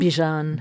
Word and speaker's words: Bijan: Bijan.